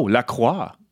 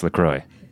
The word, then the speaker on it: LaCroix: Oh, La Croix. – LaCroix. – What? – Well, they pronounce it LaCroix.